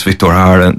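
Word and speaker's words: Víctor Jara: And amongst those was a man by the name of Victor Jara, or Victor Jara, depending on how you want to say it. I think it's Victor Jara is the correct pronunciation.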